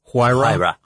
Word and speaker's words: Huayra: Huayra.